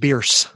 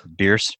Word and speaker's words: Bierce: I think it's just Bierce. – Bierce. – Yeah.